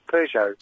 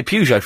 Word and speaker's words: Peugeot: Sorry, how do you say it? Peugeot. – Yeah, yes. Peugeot. – How do you say it? – I say Peugeot. I'm going to say Peugeot from now on, though.